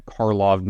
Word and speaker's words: Karlov: It is not Markov Manor. It is not Markov Manor.